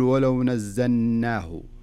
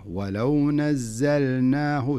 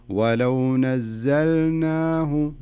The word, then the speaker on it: نزلناه: في قوله تعالى ولو نزلناه, زين لاحظ اللام مش عليها اللام, عليها سكون وسكون ظاهر بين معنى, معناها أنك لابد تنطق اللام. فلذلك لا تقول ولو نزلناه ولو نزلناه, لا خلها اللام ظاهرة ولو نزلناه, تنطق اللام ثم تنطق النون معي.